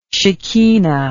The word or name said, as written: shekhinah